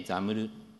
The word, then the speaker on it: XAML: 冒頭にも少し出ました、 XAML、 XAML と書いて XAML と読むんですけれども、 Windows が Vista の頃から提供を続けている UI フレームワークですね。で人によってはいまだにその Windows アプリといえば Windows Forms に代表されるようなグレーの画面を思い浮かべるかもしれませんが、それとは全く別物です。かつその XAML